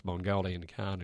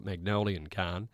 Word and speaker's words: Mongolian Khan: So the fact that I mispronounced Mongolian Khan and call it Magnolian Khan was lost on me, to be honest.